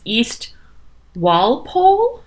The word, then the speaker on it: Walpole: W-A-L-P-O-L-E. I'm sure it's wrong. Because most things are wrong. – Every town in Massachusetts is not pronounced the way it should be. – Yeah, you like see that consonant in the middle? Don't say it.